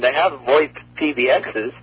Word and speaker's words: VoIP: Yeah, originally, you know, everybody was calling it voice over IP, but it's VoIP. I call it VoIP.